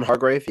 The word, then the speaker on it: Hargrave: Javon Hargrave, yeah.